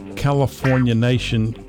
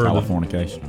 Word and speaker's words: Californication: California Nation. Well, I can't even pronounce the word. – Californication.